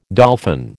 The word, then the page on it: dolphin: American English